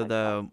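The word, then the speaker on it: Hagåtña: Hagotnya. – Hagotnya. – Yeah, so Hagotnya. With the A, it's regular, like, A pronunciation, but with the glotta, it's like a ah. – Right, okay. – Like A-W.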